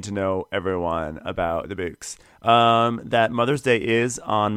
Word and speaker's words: Bouqs: Boaks, B-O-U-Q-S. – Yeah, Boaks. Like bouquet, but without the other part of Boaks.